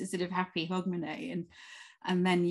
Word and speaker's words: Hogmanay: But how do you actually pronounce H-A-U-D when you're going to say it? Is it how do you say Hogmanay? Hogmanay. – Hogmanay. It's Hogmanay. I mean, it's kind of almost phonetic, at least that's how I would say it.